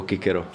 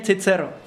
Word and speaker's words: Cicero: Je to samozřejmě Cicero, protože v našem českém prostředí eh, se tahle výslovnost v barokní době používala. O té výslovnosti typu Kikero se v té době už vědělo, ale neprosadila se do, do, do praktického používání.